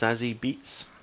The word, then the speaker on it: Zazie Beetz: I think it's pronounced Zazie Beetz.